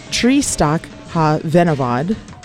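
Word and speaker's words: Tristwch y Fenywod: And it's pronounced Trístach Ha Venavod. And the song is Blóðin Gwyrth. But it looks like Tristwch I Fenywod.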